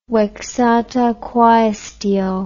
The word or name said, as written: vexata quaestio